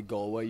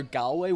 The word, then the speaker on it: Galway: First of all, let me say, I like the way you say Galway. You pronounce it 100% correctly. If I hear Galway one more time, I'm not coming back to America.